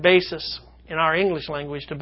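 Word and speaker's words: basis (βάσις): And that Greek word is basis. It's pronounced just like our word basis.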